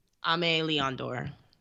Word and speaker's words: Aimé Leon Dore: um, oh, another shoe that I would put in the top ten was the um, the how do you say this brand? Amy Leondor. – Aimé Leon Dore.